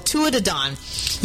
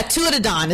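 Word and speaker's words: Tuatha Dé Danann: You would not know how that was said unless you knew how to speak Irish. Yeah, Tuatha De Dán is what I said. So let me spell it for you. – Where did all the words go? So, it's T-U-A-T-H-A. That's Tua. Duh, D-E, Fada. And then Don, D-A-N-A-N-N. Tua De Dán.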